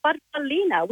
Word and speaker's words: farfallina: Well, growing up, as we're little girls, Mum called it a farfallina, which in Italian means a little butterfly.